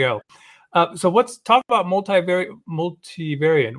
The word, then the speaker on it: multivariate: Multivariate. Is that right? Multivariate? Yes. – Yeah, usually multivariate testing.